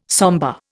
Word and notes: sombre: Indian

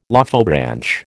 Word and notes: Lophobranch: American English

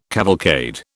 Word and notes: cavalcade: British English

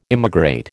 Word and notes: immigrate: American English

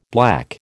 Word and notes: black: American English

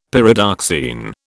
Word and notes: Pyridoxine: British English

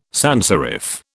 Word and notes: sanserif: British English

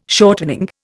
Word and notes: shortening: Indian

